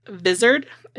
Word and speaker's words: Vizard: A child psychologist, Ellen Vizard, it's spelled like lizard, but with a V instead of an L.